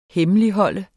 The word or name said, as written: HEMMELIGHOLD